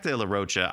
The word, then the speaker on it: de la Rocha: It features Zach De La Roca with some awesome cuts. Zach actually ends up, by the way, I've been saying his name wrong for 25, 30 years. It's Zach De La Rocha.